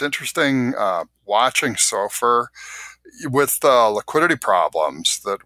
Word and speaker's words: SOFR: I got to ask you, is there an appropriate pronunciation of the acronym SOFR? – I've gone SOFR. – SOFR, okay. I've heard SOFI, even though, that's more of a naming convention than actually true to the acronym.